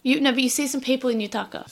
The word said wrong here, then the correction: yukata: Is that how it's pronounced? That was Yukata. – Oh, it is Yukata. I bug it up.